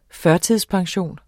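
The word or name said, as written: FØRTIDSPENSIONEN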